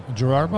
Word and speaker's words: Gerard: Am I saying it wrong? Okay. – You said Gerald. – Gerald Butler. – I'm trying to put a face to the name. Gerald. Yeah. Gerald Butler. – Gerard Butler. Yeah.